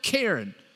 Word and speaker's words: cairn: I'm not saying the name Karen, that's two syllables. I'm saying the word Karen. Well, it's kind of like saying the name Karen if you're from Kentucky. We turn most things two-syllable into one, but let me put a little help for you up on the screen. This is a cairn.